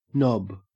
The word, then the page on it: nob: Australian English